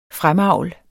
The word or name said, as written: fremavl